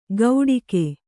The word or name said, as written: ಗೌಡಿಕೆ